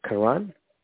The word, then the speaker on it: Karan: Karan. – Could you spell it? – K-A-R-A-N.